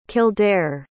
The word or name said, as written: kildaire